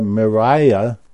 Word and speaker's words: Mirai: And this is what triggered my interest here was this week, Toyota Mirai is how it's pronounced, is a hydrogen-based car, and they went 1,000 kilometers on a single tank full.